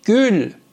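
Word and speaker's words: gül: تو تلفظا دقت بکنید من چجور تلفظ میکنم. گل. گل. فک منو ببینید. گل.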